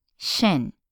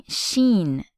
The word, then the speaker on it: shin: Now I'm talking about the shin, which is right below the knee, shin, S-H-I-N. And notice that I am not saying sheen, as in Charlie Sheen. I'm saying shin.